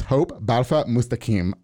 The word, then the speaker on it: Hope Balfa Mustakim: But I got to her this time, and I'd really like to, without further ado, welcome Miss Hope Balfa Mustakim on the show.